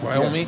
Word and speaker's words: Wyoming: Like, I said it right? Wyoming? – Yes.